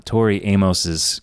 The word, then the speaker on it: Tori Amos: Well, I can tell you that the artist's name rhymes with Maury Stamos. – Okay. I wish I had a better handle on Tori Amos's catalog.